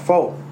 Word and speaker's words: faux: I'm like, it's pronounced faux, not fox.